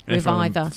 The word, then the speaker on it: RVIVR: That tune was called The Tide, and if you do want to hear more of them, then it's spelled R-V-I-V-R, Reviver.